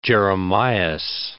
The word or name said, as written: Jeremias